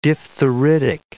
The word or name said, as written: diphtheritic